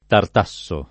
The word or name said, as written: tartassare